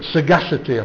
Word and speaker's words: sagacity: Sagacity, I think. I think it's a hard G.